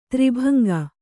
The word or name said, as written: ತ್ರಿಭಂಗಿ